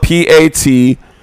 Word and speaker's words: Patoranking: I think you did a bad job on pronouncing that. – I don't. I think Petor Ranking. That's why I did. No, I did a decent job. But it's spelled Patoranking.